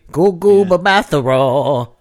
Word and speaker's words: Gugu Mbatha-Raw: Which, obviously, you say her name like you're singing ODB. Gugu Mbatha-Raw.